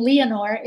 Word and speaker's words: Leonor: And it's funny, too, because my real name, Leonor, it's the Spanish version of Eleanor, and a lot of people mispronounce it.